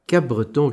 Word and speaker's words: Cap-Breton: Mais nous restons sur le continent américain, mais au Canada, à Cap-Breton, Cape-Breton, peu importe comment vous voulez le prononcer,